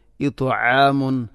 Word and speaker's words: إطعام: إطعام إطعام طاء എന്ന അക്ഷരത്തിന് പകരം തായിനെ കൊണ്ട് ഉച്ചരിക്കരുത്, വായിക്കരുത്, ഇത്ആം അല്ല.